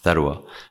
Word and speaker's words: Sarwa: So Sarwa means wealth in Arabic. It's pronounced Tharwa.